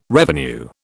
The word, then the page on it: revenue: British English